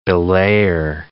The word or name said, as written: belayer